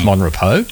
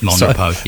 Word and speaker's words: Mon Repos: It's Mon Repos. – Mon Repos, yes. – Mon Repos, even though it's spelled, right, as you said, R-E-P-O-S.